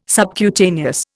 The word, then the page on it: subcutaneous: Indian